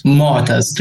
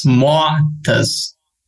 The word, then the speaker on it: Moataz: Moataz. There is a sound.